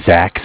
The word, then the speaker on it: Zach: My name is Zach, spelled Z-A-C-H.